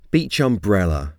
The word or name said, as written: beach umbrella